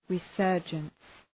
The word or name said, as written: resurgence